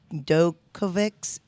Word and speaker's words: Djokovic: I'll blotch this up, but it's Djokovic's errors on COVID breaches, the tennis player.